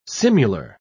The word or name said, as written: simular